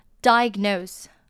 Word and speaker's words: Diagnose: Diagnosed 那它的重音就是在前面，其他它发音不变，那后面的 SE 呢是发轻微的 Z 的音 Diagnose 跟 Diagnosis 重音不太一样哦。